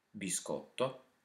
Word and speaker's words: biscotto: Biscotto.